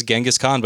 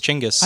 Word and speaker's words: Chinggis: Like everybody says Genghis Khan, but it's Chinggis.